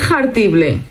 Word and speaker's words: jartible: Eso de pronunciar tanto la J ahí de jartible es una H aspirada, ¿eh? Jartible.